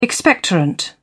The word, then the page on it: expectorant: British English